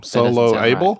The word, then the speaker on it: soloable: Solo-able? – Right. – Soloable. – Yes, they're not solo handicap. They're solo-able. – Soloable. Four syllables.